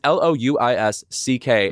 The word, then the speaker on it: Louis C.K.: But I think the number one offender of or disruptor of the name Louis is Louis C.K., his name is Louis C.K. Mm-hmm. And if you ask him how it's pronounced, he says Louis C.K.